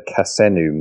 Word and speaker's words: Kasenu: And I noticed one other term that we haven't covered, and that is the, correct me if I say it wrong, the Kasenu model. – Oh, wow, you said it perfectly.